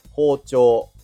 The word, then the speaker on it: 包丁: え標準のイントネーションは包丁です。王朝にアクセントで、えー、音は一定、音っていうのかなは一定とされています。